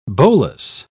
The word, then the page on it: bolus: British English